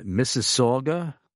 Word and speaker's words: Mississauga: So Daniel in Mississauga, Canada? – Perfect.